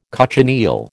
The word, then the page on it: cochineal: American English